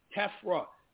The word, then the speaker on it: Tefra: I couldn't really make out exactly what it was, Tefra. – And I didn't want to misspell it. – Tefra. T-E-F-R-A.